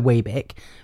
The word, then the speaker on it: Webeck: Just really quickly, also, people always call me Webeck instead of Webeck. And the annoying thing is that I've got to correct them with the correct pronunciation of my name, so Webeck, which is the shitter version.